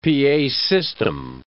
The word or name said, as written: PA system